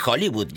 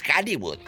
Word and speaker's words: Hollywood: Porque acuérdate, no se dice Hollywood, ya estando acá dices Hollywood. Sí.